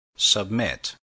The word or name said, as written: submit